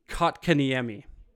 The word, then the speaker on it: Kotkaniemi: Jesperi Kokanee. – You're close. – Okay. – The J is silent. Former Montreal Canadian, now current. Yeah, Montreal Canadian. He also used to cheer for Montreal. Jesperi Kotkaniemi.